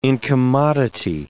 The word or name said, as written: incommodity